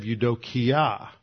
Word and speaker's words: eudokia: eudokia. That's spelled e u d o K-I-A, Eudokia.